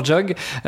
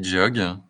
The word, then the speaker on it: JHOG: Comment est-ce qu'on prononce C'est quoi le ce qui fait vibrer tes clients En général, on dit Jog. J'ai toutes les prononciations existent. on a du Jog, on a du J-Og.